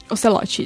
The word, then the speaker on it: Ocelote: É o Ocelote mesmo que pronuncia? É, né? Ocelote, isso.